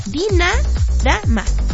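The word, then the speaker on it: Dinarama: Dinara Mara, ahí está.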